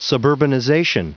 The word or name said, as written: suburbanization